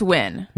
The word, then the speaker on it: Nguyen: I think it's pronounced Nguyen. – Nguyen, it's the Vietnamese name.